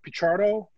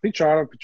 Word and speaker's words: Pichardo: They could just punch in Greg Picciardo, P-I-C-H-A-R-D-O. Sometimes so I've been saying your name wrong for 25 years now. – Picciardo, well, that's all the same.